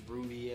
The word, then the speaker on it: RWBY: RWBY, pronounced Ruby. – Okay. I get it, man. – You don't get it. – But you don't know Ruby like I don't. Know Ruby.